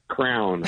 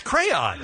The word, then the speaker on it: crayon: That's how it's said? – Yes, it's crayon. – Crayon. – I've been saying that wrong for years.